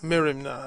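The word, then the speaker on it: merimna (μέριμνα): Merimna, I guess, is the proper way to say it, merimna.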